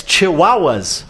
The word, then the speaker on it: chihuahuas: Yeah, it's chihuahuas. Which, of course, as everybody knows, rhymes with Tijuana, doesn't it?